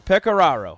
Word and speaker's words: Pecoraro: Pecoraro.